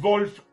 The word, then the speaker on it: Wolf: Tú dices Wolf correctamente, porque tú tienes una, pronunciación alemana austriaca, como, como Wolf era de austriaco, ¿no?